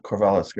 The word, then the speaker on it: Korvela: Yeah, Korvela's great.